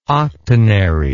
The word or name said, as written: octonary